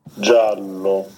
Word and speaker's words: giallo: Giallo.